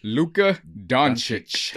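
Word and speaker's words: Dončić: Luka, how do you say his last name? – Luka Doncic. – Doncic. – Yeah. – Chich. Has been traded to y'all, to LA, to the West Coast, to the Lakers.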